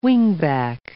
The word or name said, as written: wingback